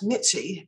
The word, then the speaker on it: Mitzi: Okay, write to me, and that's Mitzi, M-I-T-Z-I. And I don't know if anybody remembers the name Mitzi Gaynor anymore, but it's right, M-I-T-Z-I.